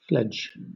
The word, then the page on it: fledge: British English